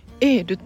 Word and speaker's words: YELL: エール